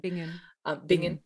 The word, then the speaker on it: Bingen: Bingen. Or Bingen, I don't know how to say the name. – Bingen.